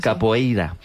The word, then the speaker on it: capoeira: Pues todos sabemos lo que es la copeira, ¿verdad? – Capoeira. – Qué mal pronuncio últimamente.